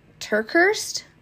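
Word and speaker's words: TerKeurst: There is a speaker, her name is Lisa, and I think her last name is pronounced Terkhurst. It's T-E-R-K-E-U-R-S-T.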